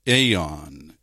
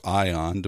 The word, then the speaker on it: aeon: aeon in the Greek, or ion, depending on how you want to pronounce it.